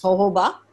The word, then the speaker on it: jojoba: It's spelled with J, it's pronounced as jojoba.